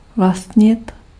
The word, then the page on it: posséder: [pɔ.se.de]